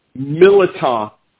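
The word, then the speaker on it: mirliton: I'd say Meliton, with there's no R, okay? – Right.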